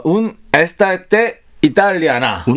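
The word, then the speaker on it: Un'estate italiana: 다시 한번 발음해 주시죠. – 운 에스타테 이탈리아나.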